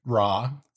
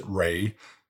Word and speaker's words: Ra: In Egypt, there were gods like Ra, some pronounce it Re, and Osiris.